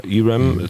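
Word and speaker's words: ERAM: Nun gab es dieser Tage die Meldung, dass die Amerikaner offenbar etwa 3500 E-RAM-Raketen, spricht man das so aus, Herr Bühler, E-RAM? – Genau. – Ja, E-RAM zur Verfügung stellen wollen.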